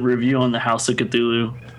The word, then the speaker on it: Cthulhu: I usually go for Cthulhu. You know, it's there's no A between the C and the T, you know, where I think a lot of people seem to kind of just blend it all in. So I think it's Cthulhu. You know, it's very quick.